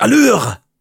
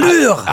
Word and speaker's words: Allure: Es heißt Allure. Also musst du es auch so aussprechen. – Allure.